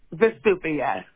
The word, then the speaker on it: Vesupia: Vesupia? How do you say that? – Vesupia. – Vesupia. – Like Vesuvius Mountain, Vesupia. It just rolls more.